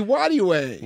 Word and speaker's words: Nwadiwe: Oh, Ify Nwadiwe. – Nwadiwe. – Yeah, yeah, the N is silent.